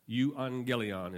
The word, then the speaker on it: euangelion: Euangelion is how you pronounce that.